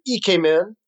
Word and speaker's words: Ikemen: Uh, you may have seen on my Facebook page recently, I just posted the second video from the EK Men. That's how you say it. It looks like Ikemen, but it's EK Men.